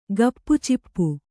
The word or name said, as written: ಗಪ್‍ಚಿಪ್